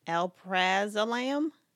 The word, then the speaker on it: alprazolam: I don't know if I said that right. That's alprazolam? – Yeah.